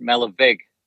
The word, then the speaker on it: Malerweg: So I certainly didn't know how to pronounce it, and evidently it's pronounced Malerweg.